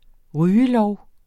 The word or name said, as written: rygelov